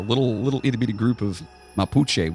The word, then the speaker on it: Mapuche: In the very southern part of South America, there lived a group of people known as the Mapuche.